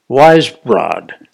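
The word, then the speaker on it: Weisbrod: Wisebrod, did I say it correctly? – I think you said it correctly.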